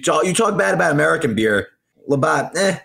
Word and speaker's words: Labatt: Labatt, did I say it right? – Labatt Blue, Blue Canadian Pilsner, of course, first try.